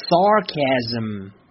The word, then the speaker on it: sarcasm: 来，跟我一起念，sarcasm。注意啊，第一个音节是 sar，要去卷舌。如果是美式发音的话，要去卷舌，sarcasm。